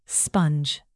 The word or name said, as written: sponge